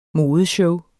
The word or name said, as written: modeshow